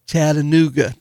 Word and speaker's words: Chattanooga: Chattanooga. Did I say it right? – You got it, yeah.